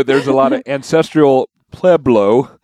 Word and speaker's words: Pueblo: In and tell us the proper way to pronunciate Pueblo. – Pueblo. – See, Pueblo. – Yeah, but you didn't say that. You said like Pueblo. – Ancestral Pueblo. – Yeah, that's better. That's better.